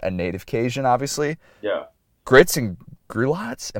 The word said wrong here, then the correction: griots: Am I saying that right? close. Though. Grits and griots.